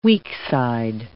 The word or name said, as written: weakside